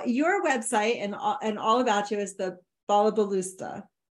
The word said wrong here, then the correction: balabusta: Did I say that right? – Balabusta. – Balabusta. I think I added an extra syllable there.